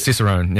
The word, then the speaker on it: Cicerone: You're a certified Cicerone. Is that the right? Is that how you say it? – Cicerone.